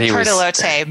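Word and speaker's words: Pertelote: How did we decide to pronounce that? Pertolote. – We said Pertolote, but we can say whatever we want because Pertolote is what's in the audiobook.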